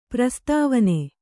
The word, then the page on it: ಪ್ರಸ್ತಾವನೆ: prastāvane